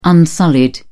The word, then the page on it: unsullied: British English